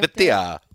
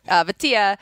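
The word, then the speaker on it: Vaitai: But Vitae, do you know how to pronounce his name? – Vitae. – Yes, that's it. Vitae.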